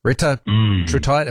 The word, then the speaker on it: Retatrutide: Retatrutide peptides. Retatrutide, have I said that right? – Yeah.